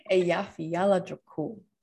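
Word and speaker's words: Eyjafjallajökull: Eja Falala Jakul. – Eja Yola Jakul. – Jakul. – I mean, you're close, Tori. Eja Fiala Jakul. And you have to say it like a lot faster.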